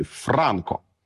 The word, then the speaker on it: Франко: Між іншим, сам наголос у його прізвищі – це результат свідомого вибору. Тобто, насправді, Франко ніхто не наголошував ні в Нагуєвичах, ні в цілій околиці, тому що це прізвище відоме по цілій Європі з наголосом на першому складі – Франко.